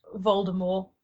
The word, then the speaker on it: Voldemort: And thus, that is how Voldemort, that's how it's meant to be pronounced.